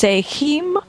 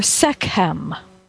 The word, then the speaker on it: Sekhem: So I'd like to start today with the tradition of Sekhem Reiki, sometimes pronounced Sekhem or Sekhem.